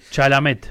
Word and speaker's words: Chalamet: Chamlet, algo así se pronuncia. – Pero sí, muy famoso. – Es muy famoso. Es como el actor de... – Chalamet. – Ahí está, Chalamet.